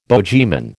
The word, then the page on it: bogeyman: American English